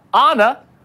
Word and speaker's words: Anna: So Lee Southwick over at our station, Gray Station WSFA, the first storm name on the list is Anna, pronounced Anna, like the Frozen character, and Elsa's also on the list, so she's asking, where's my Disney fans at?